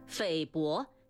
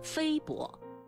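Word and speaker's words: 菲薄: 匪薄请不要读成非薄。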